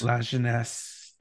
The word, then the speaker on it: La Jeunesse: So yeah, Troy dress Latiness. La Jeunesse. La Jeunesse. – It's a soft J.